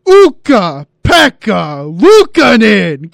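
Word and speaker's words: Ukko-Pekka Luukkonen: No, Evan, if you're going to say the name, you got to say it right. It's Uka Pekka Lukanen.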